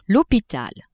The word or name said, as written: hopital